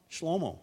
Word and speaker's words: Shlomo: Shlomo, that's how you say it in Solomon in Hebrew, said in Proverbs 6:23.